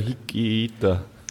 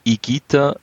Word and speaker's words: Higuita: Erst Igita, weil das H ist stumm und das GU spricht man als Gi aus.